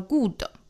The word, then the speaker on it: good: Good story, 不要读成了 good, 是 good, 短元音 w,